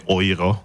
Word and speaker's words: Euro: Eh, dokonca je tam taká slovná hračka, ktorá sa vžila, že euro sa nemecky vyslovuje ojro, lebo oni EU vyslovujú ako oj v Nemčine,